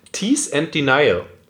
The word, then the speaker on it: Tease and Denial: Da habe ich einen Experten, lustigerweise am Start, extra zu dem Thema oder zu dem Tease and Denial. Habe ich das richtig ausgesprochen? – Ja, hast du.